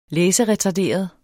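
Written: læseretarderet

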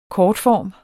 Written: kortform

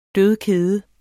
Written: dødkede

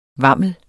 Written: vammel

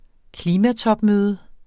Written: klimatopmøde

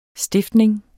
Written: stiftning

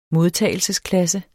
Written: modtagelsesklasse

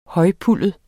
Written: højpuldet

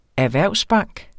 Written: erhvervsbank

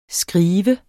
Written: skrive

